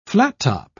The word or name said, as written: flattop